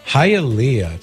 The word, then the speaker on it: Hialeah: Hialeah. So if you know a girl named Leah, you'd say Hialeah.